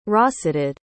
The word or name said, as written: rosetted